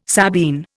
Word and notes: Sabine: Indian

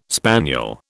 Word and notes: spaniel: British English

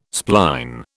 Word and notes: spline: British English

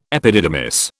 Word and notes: epididymis: British English

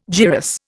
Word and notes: gyrus: Indian

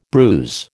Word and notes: bruise: American English

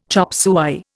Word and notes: chopsuey: Indian

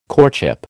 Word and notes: courtship: American English

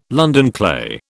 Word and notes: London clay: British English

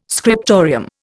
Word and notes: scriptorium: Indian

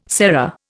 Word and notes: sera: Indian

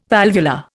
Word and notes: valvular: Indian

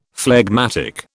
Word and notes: phlegmatic: British English

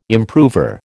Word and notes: improver: American English

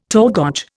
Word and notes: torgoch: Indian

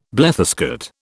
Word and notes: bletherskate: British English

